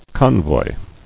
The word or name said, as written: Convoyed